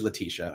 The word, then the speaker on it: Letitia: Speaking of pronunciations, I think it's Letitia. – It is Letitia. I messed it up.